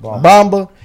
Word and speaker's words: Bamba: If Bamba, that's how you say it? – Bamba.